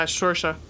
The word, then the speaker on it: Saoirse: Scorsa? – Ronan? Yeah, Saoirse.